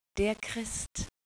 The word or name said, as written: christ